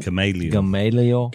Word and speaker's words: Gamaliel: You got that from, was it Gamaliel? Is that how you say his name? – Gamaliel.